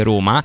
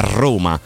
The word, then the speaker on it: Roma: Pensa che prima di, darti, prima di dare la linea a Vince ti dico anche che la R in posizione iniziale della parola si legge raddoppiata, quindi non si dice Roma ma Roma perché bisogna marcare la R.